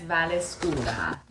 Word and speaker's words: Vallescura: Hi, everybody, and it's Vallescura.